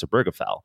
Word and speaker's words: Obergefell: For one thing, he knows that people will mispronounce his name. It's pronounced Obergefell.